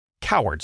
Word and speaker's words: Cowherd: His name is pronounced like Cowherd, C-O-W-A-R-D, but it's spelled like Cow Herd.